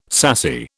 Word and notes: sassy: British English

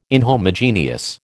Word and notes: inhomogeneous: American English

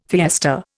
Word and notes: fiesta: Indian

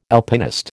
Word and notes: Alpinist: American English

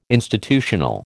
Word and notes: institutional: American English

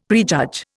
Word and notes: prejudge: Indian

